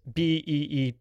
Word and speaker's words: Betelgeuse: The one spelling that you see on the TV and then how the Maitlands actually say it in the subtitles is Betelgeuse. And of course, the title on the movie is Beetlejuice, or ICE, sorry. So they're both pronounced the same way.